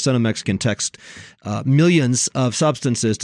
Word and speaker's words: Senomyx: They've unearthed this information about Cenomex. By the way, that's spelled S-E-N-O-M-Y-X, and Pepsi, as they've combed through the company's patents.